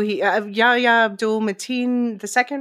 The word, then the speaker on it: Yahya Abdul-Mateen II: For anyone who doesn't know, Yahya Abdul-Mateen II, right? Did I say his name correctly? – Yes.